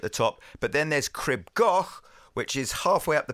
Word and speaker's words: Crib Goch: Cribgoch.